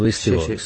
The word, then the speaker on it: Beastie Boys: Lo he dicho bien, ¿no? Beastie Boys. Sí. Perfecto.